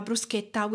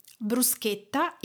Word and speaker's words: bruschetta: How to spell it? Bruschetta is B-R-U-S-C-H-E-T-T-A. So it is not bruschetta. Why? You must read CH in Italian as K, so it is bruschetta.